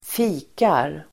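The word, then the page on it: fikar: [²f'i:kar]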